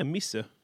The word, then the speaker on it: Mhysa: Her name is Misha. I think it's pronounced that way. It's M-H-Y-S-A. What would you say, Misha? – What does it say it again? – M-H-Y-S-A. – Yeah, Misha. – Yeah, there we go.